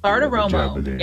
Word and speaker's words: Bartiromo: Bartiromo.